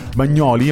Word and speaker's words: Bagnoli: Hanno provato a farlo a Bagnoli, abbiamo scoperto che si mette l'accento sulla O.